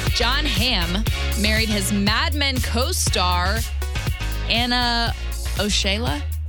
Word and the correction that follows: Osceola: I don't know how to say this. Oshiola? – It's obviously Osceola.